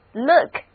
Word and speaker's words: look: 我总是希望能够再见到大家，所以今天我就要和大家聊聊看这个单词 Look, L-O-O-K, 字母 O-O 的读音是短的 U。